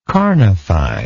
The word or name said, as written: carnify